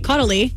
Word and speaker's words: Caudalie: It's pronounced C-A-W, which would be call. – Yeah. – D-A-H. – Caudalie. Oh. – Yeah, I think you nailed that. – Okay, I'm just going off of what it appears. – Caudalie. No, that looks right. – So it's spelled C-A-U-D-A-L-I-E.